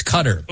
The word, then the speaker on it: Qatar: Canada's up on, how would you say this, Qatar? – Qatar. – Qatar. Okay. It's Qatar. – Okay. No, Qatar. Okay, two of the letters we don't have in English.